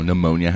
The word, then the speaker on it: pneumonia: English is, like, you know, pneumonia has a P. Like, where the fuck did that come from?